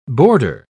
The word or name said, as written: boarder